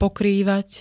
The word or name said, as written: pokrývať